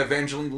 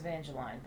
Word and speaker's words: Evangeline: Oh, and Evangeline Lilly, who was the Wasp. – It's Evangeline, but yes. – Is it Evangeline? – Yep.